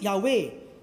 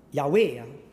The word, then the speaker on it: Yahweh: So it's actually Yahweh, if we pronounce it. We're not sure about pronouncing, but probably it's pronounced Yahweh.